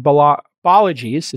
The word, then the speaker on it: Balaji: So maybe you can fill us in on Balaji's, is how you pronounce it, Balaji's big Bitcoin bet.